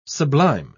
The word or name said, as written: sublime